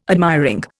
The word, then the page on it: admiring: Indian